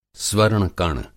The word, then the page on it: स्वर्ण-कण: Hindi